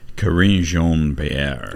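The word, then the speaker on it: Karine Jean-Pierre: Corinne Jean-Pierre.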